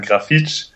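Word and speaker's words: Grafite: Kommt drauf an, woher man in Brasilien kommt, aber in Rio würde man sagen, Grafitsch, auch schon 38 Jahre alt.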